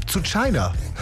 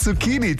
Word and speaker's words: Zucchini: Sowas wie zu China. Ja, statt Zucchini, zu China eben.